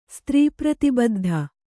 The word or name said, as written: ಸ್ತ್ರೀಪ್ರತಿಬದ್ಧ